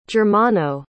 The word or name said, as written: germano